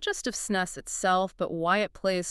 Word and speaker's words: snus: So SNUS, and it's pronounced SNUS, is basically a moist, smokeless tobacco product.